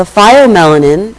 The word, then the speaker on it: pheomelanin: The pheomelanin, and I'm pronouncing it pheo, sometimes it's spelled with an A before the E.